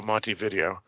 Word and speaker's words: Montevideo: This film here uh, takes place in the city of Montevideo, which I used to call Montevideo, but I think it's Montevideo, uh, if you pronounce it with the correct emphasis.